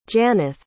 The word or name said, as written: Janeth